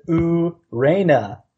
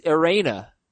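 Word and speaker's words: Ureña: But then you look at the pronunciation and it says Urena. But it's an Inye. – That's how I always. – I don't understand. There needs to be consistencies in how we pronounce these names. – Every time I watch the Marlins game and he's pitching, it's Urena. So.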